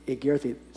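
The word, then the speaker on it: egerthi (ἠγέρθη): egerthi, starting with that long E, eta, a, gamma, epsilon, rho, theta, eta, egerthi.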